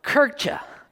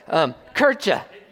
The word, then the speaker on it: Kirche: The term church is actually a derivative of the German word, and I'm going to try and pronounce this right, Kirche. Okay, if you took German in school or you have German backgrounds and I'm mispronouncing it, you can correct me later. But I listened to it on Google Translate, and that's how it pronounced it for me.